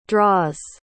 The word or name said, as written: draws